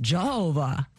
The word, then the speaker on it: Jayhover: His name is actually spelled J-A-Y-H-O-V-E-R, Jehovah, but it's pronounced Jehovah.